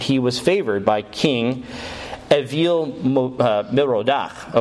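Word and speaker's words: Evil-Merodach: Okay, that's how you would actually say his name in Hebrew. But it's spelled evil, Merodach. So everyone wants to pronounce it evil, which is great because it makes him look like he's an evil guy because he's Babylonian.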